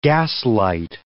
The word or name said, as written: gaslight